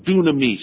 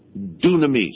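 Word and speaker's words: dunamis (δύναμις): and then IS, and this is pronounced dunamis. Dunamis.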